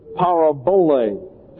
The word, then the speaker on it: parabole: It comes from the Greek word parabole, P-A-R-A-B-O-L-E, pronounced parabole. It is a compound word, para, P-A-R-A, means alongside, and bole, or balo is the actual vocabulary term, means to throw, like to throw a ball.